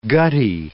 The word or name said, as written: guttae